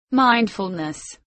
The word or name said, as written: mindfulness